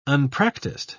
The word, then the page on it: unpracticed: British English